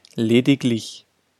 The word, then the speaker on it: lediglich: Ausnahme. Wenn die nachfolgende Silbe die Silbe lich ist, wird G gesprochen. Lediglich, königlich.